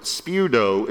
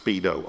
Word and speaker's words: speudo: This Greek word, speudo, is where we get the word speed from. And you can see, because of the way it's spelled there, the S-P-E-U-D-O, but it's not pronounced speedo, okay?